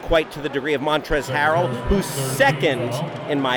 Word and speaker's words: Montrezl: M-O-N-T-R-E-Z-L. – He got it, folks. Ding, ding, Woo. Ding, ding. The L, I believe, is silent.